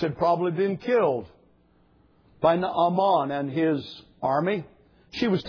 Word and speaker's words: Naaman: We call him Naaman. Two syllables in the Hebrew, Naaman.